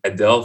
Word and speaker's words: Adele: And he played Adele for me. And I was like, who is this lady? First of all, I saw her name, A-D-E-L-E. And Nigeria, we say Adele. That's how we pronounce it.